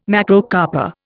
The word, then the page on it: Macrocarpa: Indian